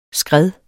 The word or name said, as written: skred